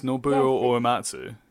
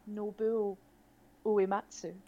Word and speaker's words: Nobuo Uematsu: It's Nobuo Uematsu. – I have written down phonetically how to pronounce his name, and you stole this moment from me. – I'm sorry. Jump in now. – Nobuo Uematsu.